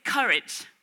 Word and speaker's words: courage: Se dice courage.